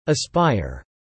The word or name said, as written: aspire